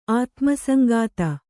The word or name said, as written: ಆತ್ಮಸಂಗಾತ